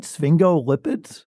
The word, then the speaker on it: sphingolipids: Sphingolipids? – Yes, you said it right.